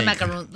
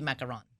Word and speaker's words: macaron: I like to call them macaroons. Like, I call them macaroons all the time. – Okay. – But the right way to say it, it should be macaron.